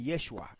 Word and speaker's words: Yeshu: Okay, porque los galileos pronunciaban el hebreo de cierta forma muy particular y algunos no pronunciaban la ayin. Esto es un dato histórico y gramatical. Algunos galileos, muchos galileos, no pronunciaban la ayin. Y como el nombre Yeshua termina en ayin, entonces en vez de decir Yeshua o Yeshua, ellos decían Yeshu.